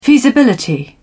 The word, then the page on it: feasibility: British English